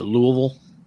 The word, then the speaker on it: Louisville: You have to pronounce it correctly. It's Louisville.